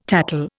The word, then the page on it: tattle: Indian